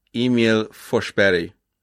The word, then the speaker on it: Emil Forsberg: Emil Forsberg.